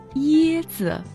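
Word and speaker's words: яцзи (椰子): Запомните, «яцзи» читается в первом тоне, а «цзи» – в легком.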